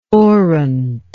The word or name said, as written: orant